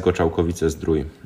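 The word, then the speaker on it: Goczałkowice-Zdrój: Ist es eigentlich richtig, äh, Goschalkowice Streu oder wie spricht man das aus? – Ja, LKS Goschalkowice Strui. Äh, Strui, okay.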